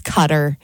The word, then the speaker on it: Qatar: And then also on here was Qatar, which I say wrong as well. It's Cutter.